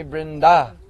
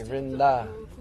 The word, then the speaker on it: Vrinda: And because she's feminine, then we say Vrinda. Long A, Vrinda. So in her form as Vrinda, she's in charge of all the leelas of Braj.